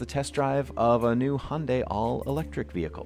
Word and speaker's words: Hyundai: They say that it rhymes with Sunday, so it should be Hyundai. I tend to say Hyundai, but it's Hyundai.